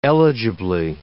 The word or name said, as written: eligibly